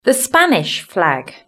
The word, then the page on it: the Spanish flag: British English